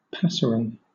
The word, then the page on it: passerine: British English